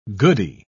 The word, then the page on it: goodie: British English